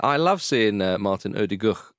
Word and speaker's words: Odegaard: Odegaard.